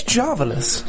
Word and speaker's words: Jarvelous: Welcome to another Jarvelish episode of Agent Carter here on AfterBuzz TV. I couldn't even say it. – It's Jarvelous. – Jarvelous. – It's a long A at the beginning.